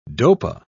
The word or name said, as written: dopa